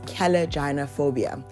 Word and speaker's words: kallogynephobia: You might want to Google that one and the actual pronunciation, because I tried to figure it out and I did try to Google the pronunciation and that's what I got. It's kallogynephobia.